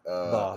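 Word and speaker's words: Baugh: The next one on the list, 31 on the list, Sammy Bo, I believe. – Ba. I think it's Ball. – It's pronounced Ba.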